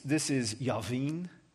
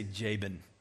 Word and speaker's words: Jabin: In Hebrew, this is Yavin, okay? I'm going to say Jabin, right? Because that's how we talk.